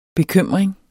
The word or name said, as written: bekymring